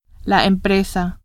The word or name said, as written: empresa